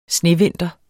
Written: snevinter

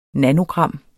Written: nanogram

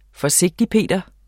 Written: forsigtigper

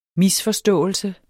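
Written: MISFORSTÅELSES

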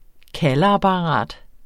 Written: kaldeapparat

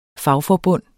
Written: fagforbund

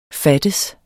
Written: fattes

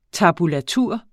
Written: tab

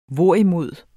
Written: hvorimod

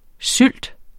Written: sylt

